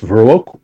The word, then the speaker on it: Viroqua: From April 18th to the 20th, you're going to be in Stoughton, Verac. Oh, I'm saying that wrong. I know Verac. – It's It's all right. I'm from Wisconsin. I can tell you it's Viroqua.